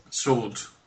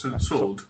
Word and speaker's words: Sword: In realtà volevo parlare velocemente di un giochino che si chiama Super Brothers Sword and Sorcery, giusto? L'ho detto giusto? – Sword. – Sword. – Scusa, no, senza doppia V, Sword.